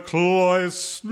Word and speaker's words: cloisters: Look at the way he romps and roisters, swallowing oysters in the cloisters. – Cloisters rhymes with oysters, but yes, apparently it rhymes with another word.